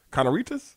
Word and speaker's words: carnitas: It said that Lincoln Riley no longer deserves their our Carnitas. Is that how you say it? Carnitas.